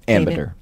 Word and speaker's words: Ambinder: It's Ambinder, right? – Ambinder. – Ambinder. Oh, Ambinder. Okay, I guess they said it wrong.